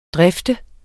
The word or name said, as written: drifte